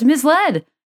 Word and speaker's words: misled: Like, it's just like funny, like misunderstandings of pronunciation, like thinking like the word misled is pronounced misled. And when you think of like tinseled or I don't know, chiseled, mizzled, you know, I wouldn't think misled, I'd think mizzled. but it's misled.